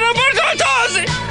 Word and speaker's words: Umberto Tozzi: If you want to pronounce that better than me. – Is it Umberto Tozzi? – Thank you.